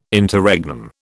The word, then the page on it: Interregnum: British English